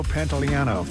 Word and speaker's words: Pantoliano: And I'm really excited about, even though I'll never go to this thing, Joey Pantalonio. Am I saying his name right? I'm not saying his name properly, am I? Joey Pants, you know this guy? Joe Pantoliano, thank you.